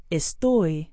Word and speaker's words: estoy: Estoy means I am. Try it. – Estoy muy bien. Estoy muy bien. Estoy muy bien. – Notice the forceful sound on the last syllable of estoy.